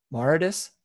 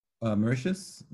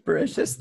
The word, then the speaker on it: Mauritius: So, Mauritius, I can't say that right. – Mauritius. – Mauritius, thank you.